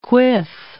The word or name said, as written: quiff